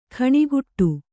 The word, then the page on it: ಖಣಿಗುಟ್ಟು: khaṇiguṭṭu